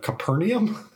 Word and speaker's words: Capernaum: It's called, I thought it was Capernaum, and I've since figured out it's actually the word Capernaum. – Okay. – It's spelled C-A-P-E-R-N-A-U-M.